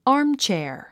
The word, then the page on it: armchair: ɑ́ːrmtʃὲər; アームチェア